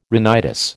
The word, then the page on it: rhinitis: American English